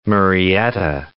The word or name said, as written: Murrieta